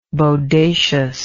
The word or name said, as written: bodaciously